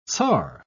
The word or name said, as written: tsar